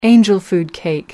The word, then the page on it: angel food cake: British English